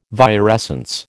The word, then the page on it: virescence: American English